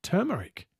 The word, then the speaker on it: turmeric: It is pronounced turmeric. The first part of the word rhymes with her.